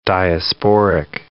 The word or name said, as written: diasporic